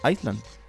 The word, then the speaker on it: Island: ¿No es Island? – Island. – La S no se pronuncia.